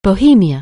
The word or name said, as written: Bohemia